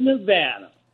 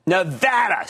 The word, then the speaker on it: Nevada: How are you from the great battleborn state of Nevada? – Nevada. See, it's pronounced Nevada, Regina. – I know. – She's always fighting with me on that.